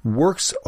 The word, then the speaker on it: Werkz: Works Holsters, that's spelled W-E-R-K-Z.